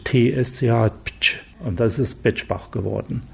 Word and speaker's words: Bexbach: Wir haben ja auch einen interessanten Ort im Saarland, Bexbach, der dann Betschbach ausgesprochen wird.